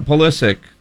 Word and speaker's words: Pulisic: My real question, I thought Pulisic...